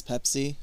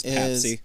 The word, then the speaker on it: Pepsi: A case of Pepsi, and the way his car pronounces Pepsi is Papsy.